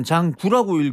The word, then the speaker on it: 장구: 장고라고 쓰고 네. – 읽을 때는 장구라고 읽으라고 선생님이 말씀해 놓으시고 항상 시험 문제를 내요.